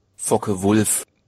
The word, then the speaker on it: Focke-Wulf: Focke-Wulf.